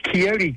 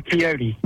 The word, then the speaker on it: Quixote: So that is Quixote Club, like Don Quixote.